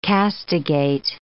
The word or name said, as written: castigate